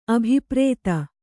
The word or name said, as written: ಅಭಿಪ್ರೇತ